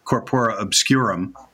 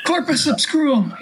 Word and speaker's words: Corpora Obscurum: We've had Seth started us up, I think, with Corpora Obscurum. Yes, that's how you pronounce it. Corpora Obscurum. He said he won't come back until you pronounce it correctly.